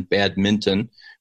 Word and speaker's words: badminton: So just to be clear, because you talked about how sometimes you'll shoot baskets or do badminton, and I like how you pronounce it with the N, badminton,